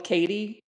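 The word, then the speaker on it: Katy: So in Ireland, I've pretty much given up being called Katie. Everyone calls me Kathy because Katie is spelled so unusually. It's K-A-T-Y.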